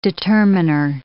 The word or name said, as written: determiner